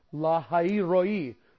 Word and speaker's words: Lahai Roi: By the well of Lahai Roi. I'm trying to preserve at least a modicum of Hebrew pronunciation there. Lahai Roi, the well of seeing, the well of provision.